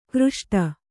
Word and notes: ಖರೋಷ್ಠಿ: kharōṣṭhi

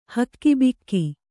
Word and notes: ಹಕ್ಕಿಬಿಕ್ಕಿ: Kannada

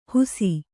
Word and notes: ಹುಸಿ: husi

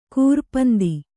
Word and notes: ಕೂರಪಂದಿ: kūra pandi

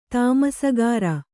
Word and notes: ತಾಮಸಗಾರ: tamasagāra

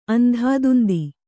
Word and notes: ಅಂದಾದುಂದಿ: Kannada

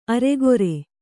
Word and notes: ಅರಗುರಿ: araguri; Kannada

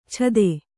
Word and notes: ಛದೆ: kannada; chade

